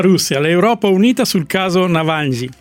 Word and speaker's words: Navalny: Navalny. – Perché io sono russo, dico la pronuncia esatta,